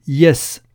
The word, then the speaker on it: jes: Pour dire oui en espéranto, on dit yes, comme en anglais, mais ça s'écrit J-E-S.